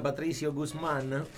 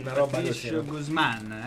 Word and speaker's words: Patricio Guzmán: Babbo, penso sia Patricio Guzmán. – Patricio Guzmán, è lui.